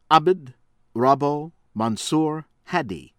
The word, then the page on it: Abd Rabbou Mansur Hadi: American English